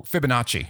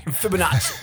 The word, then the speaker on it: Fibonacci: So you launch right in with an introduction that includes the Fibonacci sequence. Did I say that right? – Oh, Fibonacci. – Fibonacci. Thank you.